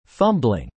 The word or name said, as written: fumbling